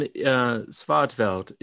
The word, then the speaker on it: Svärdfelt: Well, you were just telling us before we got on that your name, Svärdfelt, is, if I pronounced it correctly.